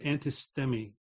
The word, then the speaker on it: antistemi: This is translated from the Greek word antistemi, I think it's pronounced. A-N-T-I-S-T-E-M-I, antistemi.